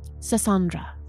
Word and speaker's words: Sassandra: Sassandra. – Oof, spell it. – Awkward. – S-A-S-S-A-N-D-R-A.